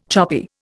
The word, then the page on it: choppy: Indian